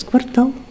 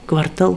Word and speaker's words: квартал: Но если вам не хочется производить такое впечатление, это, если вы думаете, ваша единственная ошибка, повторите несколько раз «квартал», «квартал», «квартал», «квартальный надзиратель».